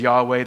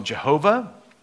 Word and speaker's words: Yahweh: And so he says, tell them I am sent me to you, and then gave Moses this name, YHWH, which we pronounce as Yahweh. There's another way to pronounce it. It's called Jehovah. That's where you take the YHWH, you take the vowel sounds from the Hebrew word Lord, Adonai, and add the vowel sounds in, and it gives you this Jehovah, or you say Jehovah. But that's a made-up way of saying it because you're just adding the vowels from another word for Lord.